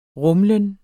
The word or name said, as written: rumlen